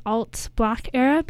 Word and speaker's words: ALTBLKERA: I think you pronounce their name as Alt Black Era, but it's spelt ALTBLKERA, all three to separate words, and they are very new.